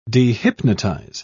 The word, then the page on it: dehypnotize: British English